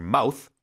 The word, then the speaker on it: mouth: TH, you can pronounce it two different ways, right? So it's like a th and a th, right? – 맞아요. – And so to like your mouth is the thing on your face, but to mouth, spelt the same way but pronounced slightly differently, is to actually make out the words with your mouth. – Right. So when it's a verb, it's a softer sound, mouth.